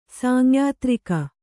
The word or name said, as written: ಸಾಂಯಾತ್ರಿಕ